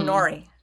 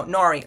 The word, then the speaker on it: Nori: I'm Nori. Nora? No, Nori. Lori? Nori. Mary? It's like, so I am so used to saying my name, and usually it's like, I am Nori.